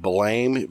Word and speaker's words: Blame!: On the website, I actually reviewed an anime called, it's spelled Blame, B-L-A-M-E, exclamation point. It's based on a manga, but it's pronounced Blam.